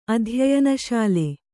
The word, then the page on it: ಅಧ್ಯಯನಶೀಲ: adhyayanaśila